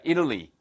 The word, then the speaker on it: Italy: 발음 하나만 주의하세요. 예, 이탈리아를 예, Italy라고 합니다. Italy. 어? 누구한테 이를 건데요? 자, Italy. 물론 이게 Italian 하고 뒤에 이제 IAN이 붙으면은 발음이 Italian이 되지만 이 국가명은 발음을 거의 미국식 발음은 Italy로 들립니다.